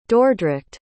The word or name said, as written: Dordrecht